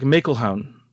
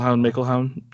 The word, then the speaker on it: McElhone: And then, yeah, it's got this sort of how you pronounce it, and it's like McElhone. So McElhone.